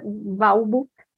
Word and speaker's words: Balbo: A Balbo, que se diz assim mesmo, se escreve com U, para quem não vai poder ver, é Balbo.